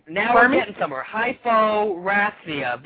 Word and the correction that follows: hyperosmia: Vinny, look that up. – Hyperosmia. – Hyper. – Like you hyper? – Okay. Yeah. Hyperosmia.